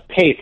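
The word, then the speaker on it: Paeth: Well, I don't know if it's cooler, but it's pronounced Paith, like faith, but with a P.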